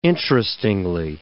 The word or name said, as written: interestingly